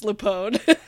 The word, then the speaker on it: LuPone: It's spelled like lupine, but it's pronounced LuPone.